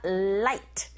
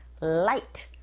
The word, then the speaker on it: light: 이때 light 발음하실 때 L 사운드니까 랄랄랄라 light, light 이렇게 발음하시고 R 사운드로 시작하는 단어는 우 하면서 앞으로 내쉬면서 right, right 철자 한 끗 차이인데 완전히 다르죠.